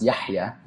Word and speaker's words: Yahya: So for those of you who don't really know me, which would be probably most of you, my name in Arabic, Yahya, it's actually pronounced Yahya, but Yahya is translated in Arabic to live, basically, to live life, so to speak.